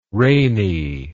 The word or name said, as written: rainey